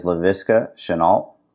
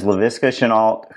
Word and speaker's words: Laviska Shenault: That is Laviska Shenault. Did I say it right? – Yeah. Yes, you did. – That is Laviska Shenault, who has 21 catches for 388 yards and 2 touchdowns.